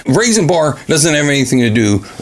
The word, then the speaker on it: Rayxanber: But I also mentioned another game, Raisin Bar, for the PC Engine. It's spelled R-A-Y-X-A-N-B-E-R. Raisin Bar.